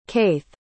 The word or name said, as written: caith